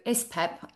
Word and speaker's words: ISPEP: So ISPEP, and it's spelled like, and it's pronounced like Pepsi backwards.